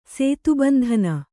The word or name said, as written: ಸೇತುಬಂಧನ